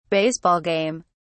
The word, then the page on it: baseball game: British English